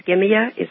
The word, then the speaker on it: Skimmia: Skimmia, does that ring a bell? – Yes, it is.